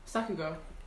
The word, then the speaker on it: sakuga: It's called like, let me just try, it's like Sigaga? I can't pronounce it. – Sakuga.